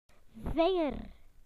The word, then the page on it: finger: British English